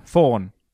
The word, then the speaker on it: fawn: A thorn? – A thorn, thank you. He's a thorn. – Fawn. F-A-W-N, not a thorn. – No, am I saying it wrong? Thorn. – You're saying it like a...